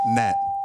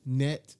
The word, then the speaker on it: gnat: K-N-I-F-E, nigga. – All right, gnat. – Net? – Gnat, like the little annoying ass bugs. – Oh. – No. GN18, nigga. The G is silent.